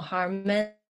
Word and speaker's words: Harman: So just search my name, Xiaofeng Harmon. Is spelled as X-I-A-O-F-E-N-G. Harman is H-A-R-M-A-N.